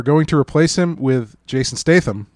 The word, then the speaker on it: Statham: And it stars Alfred Pennyworth from the TV show Gotham, Tommy from Trainspotting, but almost Jason Statham. Statham? Oh, that was me. – You just become Dutch? – That was me not committing to how to pronounce that A. – Statham.